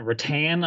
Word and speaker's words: Reattan: There was a guy also, Silent Rattan, R-E-A-T-T-A-N, like a wicker chair, like the wicker rattan.